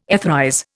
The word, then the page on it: etherize: Indian